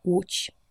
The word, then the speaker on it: Łódź: Ł wie Łódź oder Lech Wałęsa wird ausgesprochen wie ein amerikanisches W in Woody Allen.